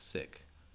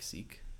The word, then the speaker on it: Sikh: I've heard that it's supposed to be pronounced more like sick, and less like Sikh.